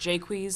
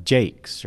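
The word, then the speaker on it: Jaques: Agrees the name is pronounced Jacques. – Well, not everyone. There are many critics who think it should have a more English pronunciation like Jakes or even Jacks.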